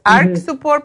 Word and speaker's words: Arch Support: Se escribe así. Es Arch Support, pero se A R C H Support.